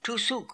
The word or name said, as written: Toosook